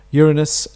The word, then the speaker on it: Uranus: Uranus and Neptune. – You're what? – I pronounced one of the planets quite correctly, didn't I? – I think you did.